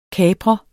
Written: kaprer

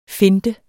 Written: finte

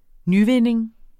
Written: nyvinding

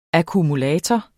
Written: akkumulator

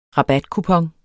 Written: rabatkupon

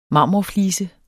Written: marmorflise

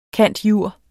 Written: cand.jur.

